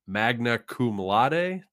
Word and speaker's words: magna cum laude: And he graduated magna cum laude. Did I say that right? – Yeah, it sounded great.